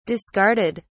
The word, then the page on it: discarded: British English